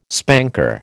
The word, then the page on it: spanker: American English